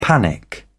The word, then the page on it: panic: British English